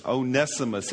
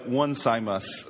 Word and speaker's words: Onesimus: Onesimus. It looks like one Simus, but it's Onesimus, okay?